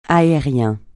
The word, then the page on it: aérien: francais